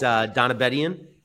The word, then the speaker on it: Donabedian: So am I saying your last name right? It's Donabedian? – Yeah, so I don't even say it right, to be honest. So I say Donabedian because that's just like the phonetic easy way to say it.